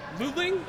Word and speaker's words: Luling: Down at the end of the table is John Van Lutling? – Yeah, Lutling, you got it.